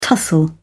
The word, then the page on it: tussle: British English